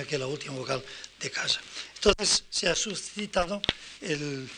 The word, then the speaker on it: casa: Así, por ejemplo, en la palabra casa, casa, esta, esta vocal final, aunque se escriba A, se pronuncia E casa.